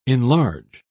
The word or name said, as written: enlarge